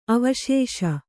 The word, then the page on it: ಅವಶೇಷ: avaśeṣa